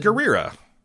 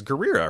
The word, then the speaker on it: Gurira: It's Gurira. I've heard her pronounce her own day. It's Gurira, right? She doesn't roll her R's or anything.